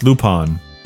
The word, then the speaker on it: Lupin: Loop on the third. – Yeah. – Wrong manga. – There's an I in there, not an O. – No, it's pronounced Lupin.